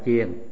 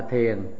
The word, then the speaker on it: chiền: Vì cái chữ đó đọc trúng phải đọc là chiền, còn đọc là thiền là trật với cái âm của chữ Nho, cái âm của tiếng Phạn.